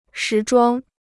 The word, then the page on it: 时装: shí zhuāng